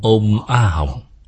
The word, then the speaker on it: Ôm A Hồng: Phát âm là như nhau, Ôm A Hồng, là ba chữ này. Ba chữ này là âm tiếng Phạn, là phát âm tiếng Phạn.